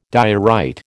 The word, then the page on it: diorite: American English